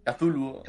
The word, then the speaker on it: Cthulhu: Cthulhu Saves Christmas. – El Cthulhu. – Cthulhu.